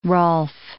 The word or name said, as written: rolf